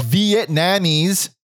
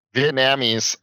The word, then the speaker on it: Vietnamese: I can't even say it because the way say it properly, Vietnamese. – Vietnamese, like we did that, we bought in a gong.